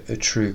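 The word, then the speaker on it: Outreau: about the Utrue case, which rhymes with Dutroux.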